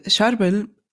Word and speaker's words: Charbel: Wie wird der richtig ausgesprochen? – Charbel. Ach so, ja. Der heilige Charbel.